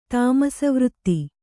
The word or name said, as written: ತಾಮಸವೃತ್ತಿ